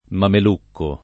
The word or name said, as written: mamelucco